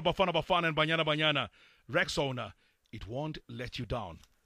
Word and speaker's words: Rexona: Guys, I'm sure you've seen that shield is now known as Rexona. But it appears some people don't know how to pronounce this new name. A friend even called it Rexona. But guys, it's Rexona. Like Rex and Ona.